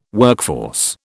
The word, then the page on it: workforce: British English